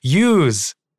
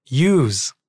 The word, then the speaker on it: ewes: Yes, I realized we had trouble pronouncing use, which is like a baby goat, I believe. But E-W-E-S. And we had to look it up. Use. That's how you pronounce it.